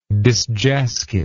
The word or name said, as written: disjasked